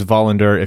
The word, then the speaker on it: Wallander: It's William Wallander, or Wallander, depending on how you want to pronounce that. I believe it's Wallander if you go by the Swedish pronunciation. Am I correct? – Which we've already established we won't do.